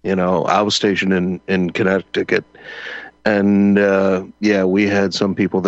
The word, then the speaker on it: Connecticut: It is Connecticut, and you're right.